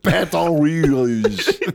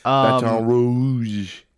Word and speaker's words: Baton Rouge: It takes a lot of work to get three syllables into the ooh sound. But. – Baton Rouge. You gotta really feel the O and the U both, you know? – Yeah.